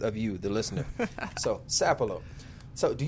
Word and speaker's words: Sapelo: Did I say it right? – Sapelo. – Sapelo. It's yeah. – Sapelo? – Yeah. – Sapelo. Oh, it's just like Sap. – Yes. – Sap. Sapelo. Okay.